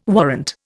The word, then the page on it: warrant: Indian